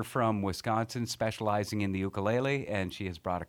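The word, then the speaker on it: ukulele (ʻukulele): Yes, the ukulele is how it's correctly pronounced, but I also like to say it's correctly spelled with an okina before the U, which is an apostrophe. So usually I say ukulele.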